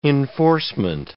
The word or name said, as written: enforcement